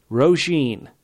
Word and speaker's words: Roisin: And now let me say this correctly. Roisin, a.k.a. Rosie the Clever, a.k.a. at Roisin Gleason on Twitter. That's R-O-I-S-I-N Gleason on Twitter.